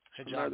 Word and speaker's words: hijab: But we actually finally show a crew member wearing a habib. Hijab. The pronunciation right.